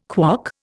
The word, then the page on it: KWOC: Indian